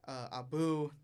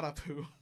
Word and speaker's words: Abu: Abu, not Apu.